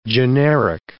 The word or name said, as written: generic